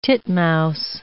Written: titmouse

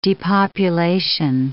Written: depopulation

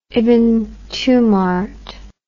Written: ibn tumart

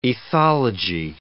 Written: ethology

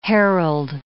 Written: herald

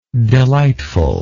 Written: delightfulness

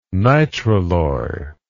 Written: nitralloy

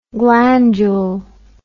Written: glandule

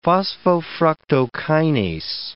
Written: phosphofructokinase

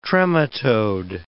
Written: trematode

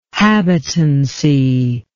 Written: habitancy

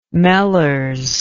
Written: mellers

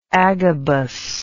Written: agabus

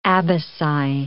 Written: abaci

